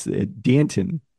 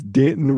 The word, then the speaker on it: Danton: I saw how this is pronounced, and now I have, oh, it's Danton Lynn, the son of former NFL head coach Anthony Lynn, who chose this name to rhyme with Canton. It's Danton rhymes with Canton.